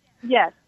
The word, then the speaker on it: Elisha: Michael, you are Elisha versus Elisha. Is that correct? – Yes. – Elisha. All right, that's how the name is pronounced biblically, Elisha.